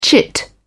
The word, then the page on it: chit: British English